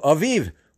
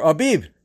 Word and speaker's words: Aviv: For example, in the month of Aviv, or Abib, depending on how you say it, from the Chaldean and the Hebrew, it shall be the first month of the year.